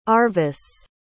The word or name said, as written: Arvis